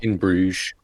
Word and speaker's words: In Bruges: I don't know how to pronounce that title. – In Bruges. – In Bruges, thank you.